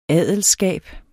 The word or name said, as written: adelskab